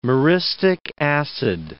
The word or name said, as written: myristic acid